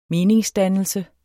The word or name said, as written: meningsdannelse